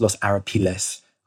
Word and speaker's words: Los Arapiles: So the British are in a kind of L shape defending a series of heights around a position called the Arapiles, Los Arapiles, I believe is the Spanish pronunciation.